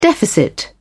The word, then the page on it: deficit: British English